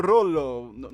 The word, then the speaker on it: Rolloe: Ecco Rollo. Rolloe si scrive.